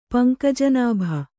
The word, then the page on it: ಪಂಕಜನಾಭ: kannada